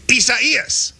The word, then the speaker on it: Isaias: I-S-A-I-A-S. – And it's four syllables. I do know that. I-S-A-I-A-S. And it's like... – I-S-A-I-A-S.